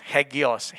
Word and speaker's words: Hagios: New Testament in Greek is called, I got to pronounce, I'm not a Greek student, so I try my best, Hagios, H-A-G-I-O-S. Okay, Hebrew is called Kodesh, Q-O-D. H-E-S-T. Greek is Hagios. H-A-G-I-O-S.